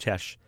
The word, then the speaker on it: Chex: And we drive through the market town, and we ask, you know, where's this village called Chesh, C-H-E-X, like Chex, the cereal, but pronounced Chesh.